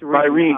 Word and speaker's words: Rene: And actually, her name is spelled Renee, but she pronounced it Wren, correct? – Correct. You've got it. – Yeah. Yeah. – Actually, she went by Reen. It's Reen. R-E-N-E.